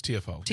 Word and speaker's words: Tiafoe: is it Tiafoe? Is that how you pronounce it? – Francis Tiafoe. – Tiafoe. Yeah. So Francis Tiafoe and Ben Shelton played yesterday,